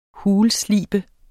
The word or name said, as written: HULSLEBET